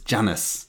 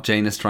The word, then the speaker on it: Janus: But yes, you announced her as Samantha Janus, but she's very publicly on record that it's Janus. – Oh, whoops. – Not Janus rhyming with anus.